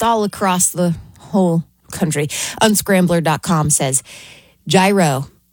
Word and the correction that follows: gyro: Oh, I said it wrong right off the bat. Yiro takes the top spot.